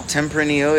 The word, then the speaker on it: Tempranillo: Tempranillo? – A Tempranillo. – Tempranillo, is that how you say it? – Yeah.